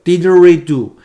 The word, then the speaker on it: didgeridoo: 其实那个英文或者说它土著人的名字叫做 didgeridoo 啊，大家记住这发音啊，叫 didgeridoo，一种用大木管子制造的一个很长的一个土著的乐器，我看长的得超过两米，呃，是澳洲土著部落的一个传统的乐器，也是世界上最古老的乐器之一。